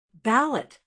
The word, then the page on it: balut: American English